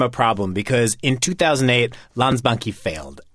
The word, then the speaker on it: Landsbanki: Landsbanki. – Landsbanki.